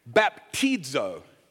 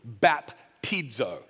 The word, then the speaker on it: baptizo: They took this Greek word, baptizo, is how you pronounce this. And some of you are like, wow, knows Greek. No, I know Google, okay? So I Google how to pronounce baptism in Greek, and you can press on a little button that actually says it out loud for you, baptizo. That's how you say it.